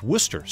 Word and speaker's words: Worcestershire: It's spelled Worcestershire sauce, but it's pronounced Worcester.